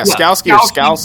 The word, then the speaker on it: Skalski: So like they were playing without Skowski, right? I think that's how you pronounce it. – Skalski? Yeah, Skalski.